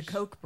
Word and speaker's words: Koch: Coke, they pronounce it Coke? Is that what they think? The Coke brothers? – The Coke brothers.